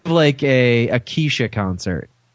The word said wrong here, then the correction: Kesha: I think it's pronounced Kesha.